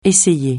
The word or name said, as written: essayer